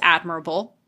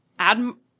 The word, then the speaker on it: Admiral: And that bromance, paired with Captain Morgan's seemingly effortless string of successes, won him the promotion to Vice Admirable. – Admiral.